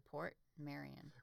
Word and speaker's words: Portmeirion: It's a Port Marion. – Well, it's one word, P-O-R-T-M-E-I-R-I-O-N.